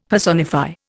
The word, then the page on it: personify: Indian